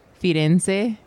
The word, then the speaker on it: Firenze: Firenze. Is that how you say it? – Firenze.